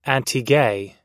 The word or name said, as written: antigay